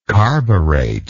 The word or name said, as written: carburet